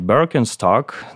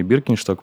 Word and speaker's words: Birkenstock: Правильно его, конечно, произносить Birkenstock, но у нас уже привычное произношение Birkenstock, поэтому Э, пусть будет так.